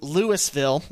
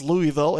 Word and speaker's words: Louisville: They fall to Louisville. Yes, I learned that it's not pronounced Louisville, it's Louisville. 59-41.